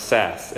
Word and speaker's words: Sasse: There's a senator from Nebraska named Ben, I think his last name is pronounced Sass, S-A-S-S-E.